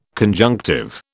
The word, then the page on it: conjunctive: British English